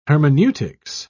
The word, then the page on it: hermeneutics: British English